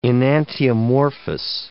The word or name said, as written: enantiomorphous